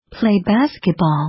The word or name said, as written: play basketball